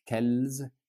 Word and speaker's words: quel: But all of them are pronounced quel, quel. So not quels, quelles, quelle. No, no, no. Quel, quel, quel.